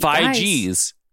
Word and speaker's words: Feige: Feige's. All right. – It's a hard G.